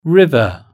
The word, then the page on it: river: British English